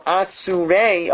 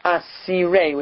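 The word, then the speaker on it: Asirei (אסירי): So Asurei, although it's written with a Vav correctly, it's pronounced Asirei with a Yud. Aleph Samach Yud Reish Yud.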